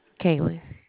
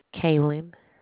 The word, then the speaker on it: Kaylen: Kaylin. – Ellie, spell it. – Kaylin. – Spell it. – K-A-Y-L-E-N.